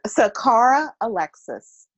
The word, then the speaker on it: Saqara Alexis: It is Saqara Alexis.